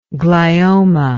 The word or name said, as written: gliomatous